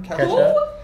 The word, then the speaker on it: Kesha: Even Kesha, is that how you say her name? – Kesha.